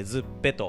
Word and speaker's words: ZUPPE: ルオープンしたお店を、えー、紹介したいと思います今日のトークテーマをお願いしますはいズッペはい、えー、ズッペというお店なんですが、はい、えー、アルファベットスペル大文字で ZUPPE と書いてズッペと、